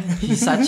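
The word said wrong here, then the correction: Hysaj: siapa? Hisaj. – Husai bacanya.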